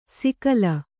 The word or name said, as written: ಸಿಕಲ